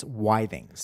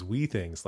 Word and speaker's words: Withings: It's Withings. – See, I always thought it was Wii Things, like it sounded like the Nintendo thing.